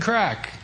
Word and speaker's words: craic: We had some good crack. It's spelled C-R-A-I-C.